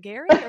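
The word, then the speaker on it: Gerry: It is pronounced Gary, okay.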